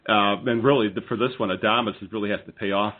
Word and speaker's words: Adames: Adames. – Adames, yes, Willie Adames, who also goes to Tampa Bay, and Nick Franklin goes from Seattle to Tampa Bay as well.